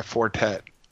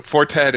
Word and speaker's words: Four Tet: So one thing to add to your Mad Villain recommendation is there's a remix album by Fortet of Mad Villain remixes. – Ooh, Fortet. How do you spell that? Really is that four? T-E-T. – F-O-U-R-T-E-T. So Fortet is like a kind of jazzy electronica guy.